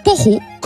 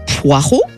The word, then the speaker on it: poireau: Les dictionnaires considèrent poreau comme un archaïsme, mais vous avez toujours le choix entre poireau, conforme au français de référence et donc légitime, et une prononciation conforme à son origine latine, ce qui est une forme de distinction aussi.